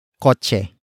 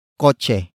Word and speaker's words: kotse: And that object is koche. Koche. Koche. Koche is spelled as K-O-T-S-E.